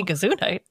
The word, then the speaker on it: Gesundheit: He purchases 105 acres in West Virginia to construct the future Gesundheit Institute. I probably murdered that word. – I think you said it right. If you meant to say Gesundheit.